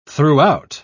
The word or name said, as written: throughout